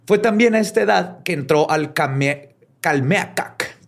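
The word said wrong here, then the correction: Calmécac: Calmecac. Calmecac. Es que tiene acento en la de Calmecac. Calmecac.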